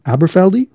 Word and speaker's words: Aberfeldy: So apparently blended from around 40 different scotches, with the heart of it being from the Aberfeldy.